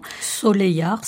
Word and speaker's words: Soleillard: Soleillard, si je ne me trompe. – Exactement, bravo. En occitan, c'est comme ça que ça se prononce, tout à fait.